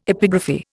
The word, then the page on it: epigraphy: Indian